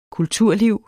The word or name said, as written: kulturliv